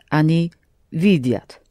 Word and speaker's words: видят: Они видят. – But stress remains on V. – Они видят.